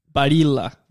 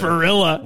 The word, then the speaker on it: Barilla: But, yeah, what did you, it's Barilla? – Barilla. That's how you say it. – Barilla. That's what I say. – Barilla. – Like a gorilla.